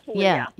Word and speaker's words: Puglia: Puglia? – In Puglia, Puglia.